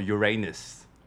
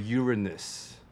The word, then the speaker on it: Uranus: 天王星的英文其实有两种不同的发音。小时候我学的它叫做 Uranus，嗯哼、 uh，huh. 但不觉得 Uranus 听起来像你的屁眼吗？是 Uranus，好烦哦。 – 所以其实，嗯，另外一个发音就是有人发音为 Uranus。 Uh huh. 但 Uranus 你不觉得听起来就很像 urine 尿吗？ – 对，我刚刚想。